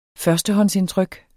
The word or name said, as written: førstehåndsindtryk